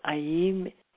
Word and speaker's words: Aim: Aim, Aim, spelled A-I-M and pronounced as so, or A-Y-E-E-M.